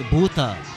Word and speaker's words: Bhuta: You have to say, you can't just say booth. It's not like, you know, like a telephone booth. It's a Bhuta, okay?